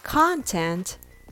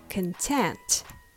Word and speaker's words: content: Content has the stress on the second syllable, so the first syllable is reduced. It becomes smaller and weaker, like can, can, content. Other words like computer, control, compare, all has the first syllable reduced. So it's not pronounced as con it becomes kin.